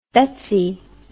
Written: Betsi

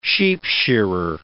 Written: sheepshearer

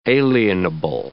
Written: alienable

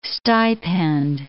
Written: stipend

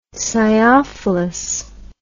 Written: sciophilous

